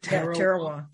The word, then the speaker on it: Tarawa: And I think Tarawa, is that how you say it? – Tarawa.